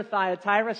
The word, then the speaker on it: Thyatira: That's how I learned to pronounce it when I was a new believer, Thyatira, so I'm sticking with that pronunciation.